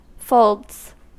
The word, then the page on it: folds: American English